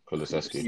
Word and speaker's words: Kulusevski: I think Kulishevsky, Is that how to pronounce it? – Yeah, Kulusevski.